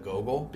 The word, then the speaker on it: Geogle: We're going to just go with Gogol, because Google was a little too familiar. – Yeah, it's spelled G-E-O-G-L-E.